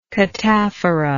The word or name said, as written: cataphorically